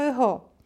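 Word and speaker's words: echo (ἐγώ): Jest to nic innego jak greckie słowo tak wymawiane u nas z zachodnią wymową. Grecy tak nie mówią. Nie mówią ego, tylko echo, czyli ja.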